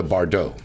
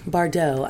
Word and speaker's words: Bardot: The Bardot. – Bardot. I knew Bardot. That. Oh, yeah. like Rebecca Bardot. Mm-hmm. But they spell it very differently. – Well, that's the French way, baby.